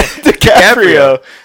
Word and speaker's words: DiCaprio: DiCaprio.